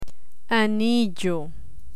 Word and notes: anillo: spanish

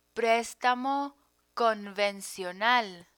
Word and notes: prestamo convencional: Spanish

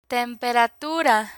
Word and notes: temperatura: spanish

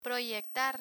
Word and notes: proyectar: Spanish

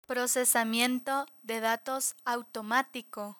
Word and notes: procesamiento de datos automatico: Spanish

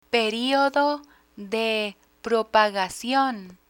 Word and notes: periodo de propagacion: Spanish

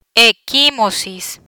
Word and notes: equimosis: Spanish

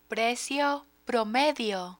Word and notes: precio promedio: Spanish

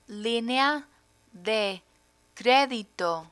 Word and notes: linea de credito: Spanish

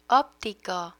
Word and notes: optico: spanish

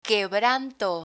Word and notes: quebranto: Spanish

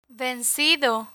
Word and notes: vencido: Spanish

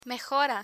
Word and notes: mejora: Spanish

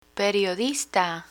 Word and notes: periodista: Spanish

